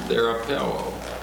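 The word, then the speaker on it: therapeuo: It's pronounced therapeo, therapy. This is the verb from which we get that word.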